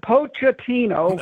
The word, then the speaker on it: Pochettino: And they were expected – Coach Pochettino, am I saying that right? Pochettino. – That's right.